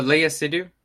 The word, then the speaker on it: Léa Seydoux: But the reason he's a fucking weirdo, especially, is with uh, say her name one more time. Uh, Léa Seydoux. Leia Léa Seydoux.